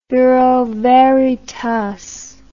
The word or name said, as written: bureau veritas